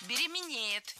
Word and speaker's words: беременеет: Например, если мужчина ценит свою женщину, она беременеет, у него падает тестостерон. – Беременеет. Неверно. Правильный вариант – беременеть, беременеет. Ударение в этом слове ставим всегда на второй слог – беременеть.